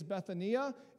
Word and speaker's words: Bethania: It's Bethania is actual the Hebrew pronunciation of this, and it is completely different.